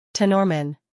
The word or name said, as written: Tenormin